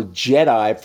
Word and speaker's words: GEDI: We also have an instrument on the International Space Station called the Global Ecosystem Dynamics Investigation, or JEDI, for all you Star Wars fans out there. But it's spelled G-E-D-I, not J-E-D-I.